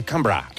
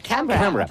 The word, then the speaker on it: Canberra: que no se dice Canberra, se dice Canberra. – Canberra.